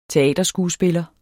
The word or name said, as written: teaterskuespiller